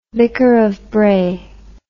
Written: vicar of Bray

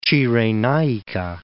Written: Cirenaica

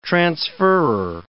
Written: transferrer

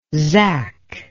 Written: zach

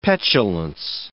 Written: petulance

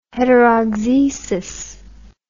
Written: heterauxesis

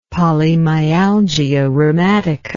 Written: polymyalgia rheumatica

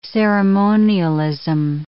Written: ceremonialism

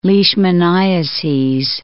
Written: leishmaniases

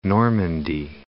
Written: Normandy

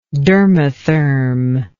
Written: dermotherm

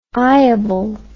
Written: eyeable

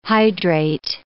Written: hydrate

